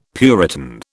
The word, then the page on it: puritan: British English